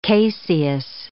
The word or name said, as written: caseous